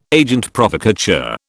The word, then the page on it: agent provocateur: British English